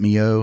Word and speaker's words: MiO: If you take water and you put Mio, is it Mio? Am I saying it right? – Yeah.